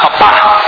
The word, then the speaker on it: papá: Por ejemplo, papá tiene el acento en la última A, papá.